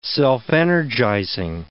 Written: self-energizing

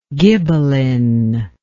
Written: ghibellinism

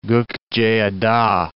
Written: Gokceada

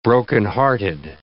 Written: brokenhearted

